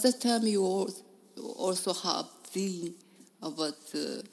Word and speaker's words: zine: It's pronounced Zine. – Zine? So Zine. Yeah. Zine. Mm-hmm. Okay. So, yeah, and it comes from the word fanzine. Fanzine. Mm-hmm. Kind of like magazine, the same pronunciation.